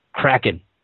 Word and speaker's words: Kraken: And yes, they pronounced their name Kraken.